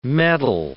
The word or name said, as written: metal